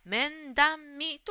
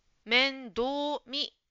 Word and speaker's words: 面倒見: 10 番の答えの面倒見という言葉ですが、ジムさんは面談見と言っていました。これもちょっと発音が違いましたね。面倒見、面倒見です。